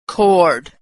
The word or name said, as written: chord